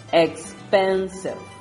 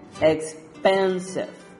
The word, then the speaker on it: expensive: Les leo, number three, expensive, expensive. That's right, it's in pen, expensive.